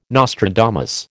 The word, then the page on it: Nostradamus: American English